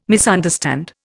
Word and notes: misunderstand: Indian

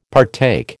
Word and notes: partake: American English

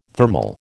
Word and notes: thermal: American English

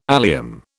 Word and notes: allium: British English

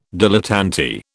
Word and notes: dilettante: British English

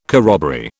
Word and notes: corroboree: British English

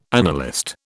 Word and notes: analyst: British English

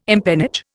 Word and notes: empennage: Indian

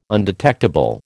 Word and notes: undetectable: American English

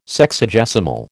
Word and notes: Sexagesimal: American English

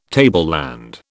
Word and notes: tableland: British English

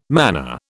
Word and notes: mana: British English

